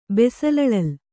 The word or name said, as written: ಬೆಸಲಳಲ್